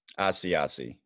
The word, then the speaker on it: Asiasi: Asiasi, yes. – Asiasi. – Like an Asiago bagel, but no G.